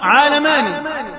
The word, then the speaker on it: عالماني: لأن القياس الصحيح نطقها صرفيا أن تطلق عالماني، لكن رفضوا هم هذه الكلمة.